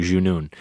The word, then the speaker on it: Junun: Okay, so after the second time, the tweets start coming in and the emails start coming in and people are telling us that we're pronouncing it wrong, that it's really Junun.